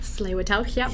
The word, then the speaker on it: Tsleil-Waututh: Am I saying that correctly? – Tsleil-Waututh, Yes.